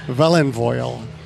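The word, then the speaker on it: Felinfoel: And it was a Vellenfoil, or they if you're Welsh, you would pronounce it Vellenfoil.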